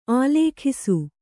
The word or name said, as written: ಆಲೇಖಿಸು